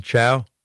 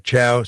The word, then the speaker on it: Chao: And Trump's the guy that insulted his wife, insulted her ethnicity, Elaine Chao. And he calls her, you know, Chow, C-H-O-W, instead of the correct pronunciation of the name.